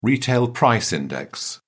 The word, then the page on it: retail price index: British English